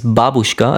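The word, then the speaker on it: Babuschka (бабушка): By the way, übrigens, im Russischen ist es Babuschka, also der Akzent ist auf der ersten Silbe.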